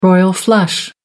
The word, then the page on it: royal flush: American English